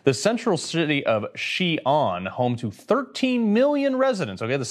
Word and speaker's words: Xi'an: All right, COVID lockdowns in China as cases surge in the central city of Xi'an.